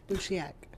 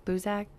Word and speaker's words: Buziak: Buziak. – Yep. Buziak.